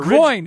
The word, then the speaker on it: groin: But groin, it's one syllable.